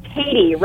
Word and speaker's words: Tady: It's actually Tady. Rhymes with lady, yes.